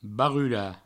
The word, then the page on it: barrular: Provençal; barrula